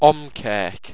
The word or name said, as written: omqerk